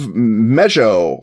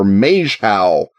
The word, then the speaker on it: Maeshowe: It's an illustration of the tomb of Mejo or Mejhow. Uh, Mejo is, I think, how they pronounce it, or Meju on the Orkney Islands and,